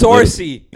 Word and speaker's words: Saoirse: Saoirse!